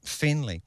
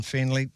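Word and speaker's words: Feneley: The director and producer, for the most part, is Dustin Fenley, and he's jettisoning two E's in the pronunciation there. If you want to look him up, it's F-E-N-E-L-E-Y. So Dustin Fenley, director of Stray, welcome along.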